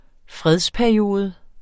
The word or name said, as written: fredsperiode